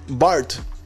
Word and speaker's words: Bard: Y te voy a hablar de BART, que Google lanza esta, esta plataforma que se escribe BARD, que es su propio chatbot, modelo de aprendizaje de idiomas, y lo lanzó el día de ayer.